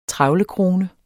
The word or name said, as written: trevlekrone